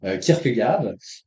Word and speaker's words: Kierkegaard: Entre-temps, vous avez aussi discuté avec Lydia Yeager de Blaise Pascal et également, je vais le dire avec la prononciation française habituelle de Søren Kierkegaard.